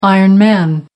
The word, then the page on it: iron man: American English